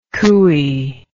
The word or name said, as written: cooee